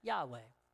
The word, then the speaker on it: Yahweh: It's just four letters, or radicals as they call them in Hebrew, a YHWH. We pronounce that as Yahweh.